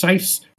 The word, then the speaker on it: Seiss: A man called J.E., and I think it's pronounced Seiss, it's S-E-I-S-S, he remarks,